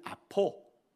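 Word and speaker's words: 아포 (ἀπό): 하나는 아포, 아포. 아 몸이 아파하는 그 아가 똑같은 발음이에요. 아포.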